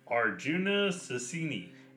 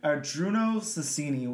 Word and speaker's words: Arjuna Susini: Arjuna Susini. – And we should be saying that correct because this is a very good artist. I like this artist a lot. So Arjuna Susini was born in somewhere in Italy that I can't say.